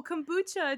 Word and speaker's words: kombucha: Is that how you pronounce that word? No, kombucha. It's a U.